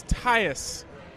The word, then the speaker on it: Tyus: And also using the word, and also if you're trying to find synonyms of, and then that happened, or like furthermore, thus browser. – Thus browser? – Thus browser. – That's another one that's just, no, it's Tyus. – It's spelled T-H-U-S. – No, T-Y-U-S.